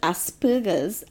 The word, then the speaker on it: Asperger's: After being diagnosed with Asperger's at a young age, just want to stop there to say, I know it's not Asperger's, like Asperger's, but you had to admit the way it's spelt, it makes it difficult.